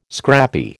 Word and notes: scrappy: American English

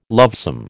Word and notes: lovesome: American English

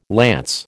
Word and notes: lance: American English

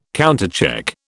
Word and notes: countercheck: British English

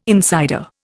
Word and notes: insider: Indian

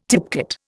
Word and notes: tipcat: Indian

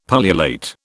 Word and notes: pullulate: British English

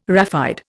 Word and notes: raphide: Indian

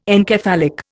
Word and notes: encephalic: Indian

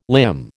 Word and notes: limn: American English

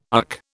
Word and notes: UC: British English

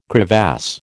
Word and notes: crevasse: American English

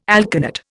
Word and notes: alkanet: Indian

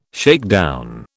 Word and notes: shakedown: British English

